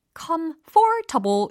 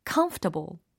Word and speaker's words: comfortable: comfortable and easy 라는 형용사가 들렸는데, 특히 편안한 이라는 형용사로 comfortable 이라고 잘못 발음하시는 분들이 계십니다. comfortable 이 아니라 comfortable, comfortable. 이렇게 강세를 지켜주셔야 된다는 센스 예, 아시겠죠?